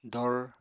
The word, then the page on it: dor: apatani